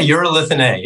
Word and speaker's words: urolithin A: Is it urolithin A? – Yeah, urolithin A.